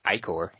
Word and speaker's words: Ichor: So essentially his question is, he's attacking with an Ichor Clawmere, because that word is pronounced Ichor, even though no one does it correctly.